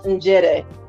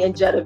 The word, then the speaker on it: Njeri: Because it's really pronounced Njede, but I just do it phonetically now, just because it's easier for people when they meet me. But you can call me Njede, because everybody still calls me, my family calls me Njede.